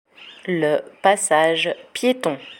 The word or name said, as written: le passage pieton